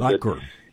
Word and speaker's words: Vivek: I know Vivek Ramaswamy. I'm never sure if I'm pronouncing that right. – Viker. – What's that? – It's Viker Ramaswamy. – Viker. Okay. Well, that's a pronunciation I've heard.